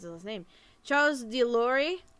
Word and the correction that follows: de Loire: Charles de Loire. – Loire. Thank you. There it is.